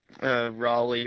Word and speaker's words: Raleigh: Riley. – Riley? – Yeah. – Is that how you say it? Riley? – Raleigh or something like Raleigh. – That. – Raleigh. – Yeah. Something close.